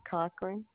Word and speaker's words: Cochran: Yes, Esther Cochran. – Can you spell that last name for me? – C-O-C-H-R-A-N.